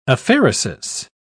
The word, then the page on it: aphaeresis: British English